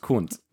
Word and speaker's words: queynte: Yeah, the word, this is the quote, by the Q-U-E-Y-N-T-E, oh. – And it's pronounced cunt.